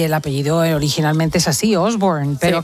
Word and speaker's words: Osborne: lo estaba discutiendo antes porque no sabía cómo pronunciarlo. – ¿En inglés? – Sí, porque claro, yo aquí escuchaba que Osborne. – Dicen exactamente Osborne.